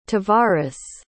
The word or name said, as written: tavaris